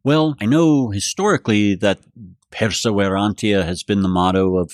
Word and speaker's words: Perseverantia: Perseverantia.